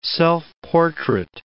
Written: self-portrait

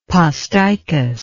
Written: posticous

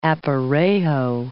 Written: aparejo

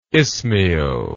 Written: ismael

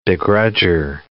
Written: begrudger

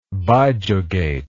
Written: bijugate

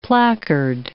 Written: placard

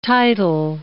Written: title

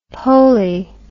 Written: poley

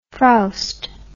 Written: frowster

